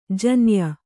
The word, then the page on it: ಜೋನಾಯ್: jōnāy